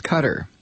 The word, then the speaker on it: Qatar: It is not pronounced Qatar. It's pronounced Qatar. Qatar. This is the way the people of Qatar pronounce it. And it is how any sailor who has spent any time in the region pronounces it.